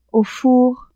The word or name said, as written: au four